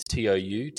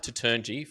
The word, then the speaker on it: Toutounji: It's Toutounji. It's pronounced Toutounji. For anybody that says, hey, there's that Richard T. Bold guy out there, it's Toutounji.